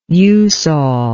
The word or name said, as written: eusol